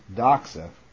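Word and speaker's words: doxa (δόξα): The Greek word, it is pronounced phaneroo for the word 5319, and then doxa for the word glory, 1391.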